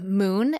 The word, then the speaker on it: Mune: It's a movie called Moon, and that's spelled M-U-N-E.